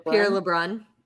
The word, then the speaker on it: Pierre LeBrun: Pierre LeBrun.